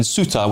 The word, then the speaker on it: Ceuta: I know that there's been reports of people in Ceuta, which I think is the correct pronunciation, as I've been told by James here on your say, who have been attacked by some of these young men as well.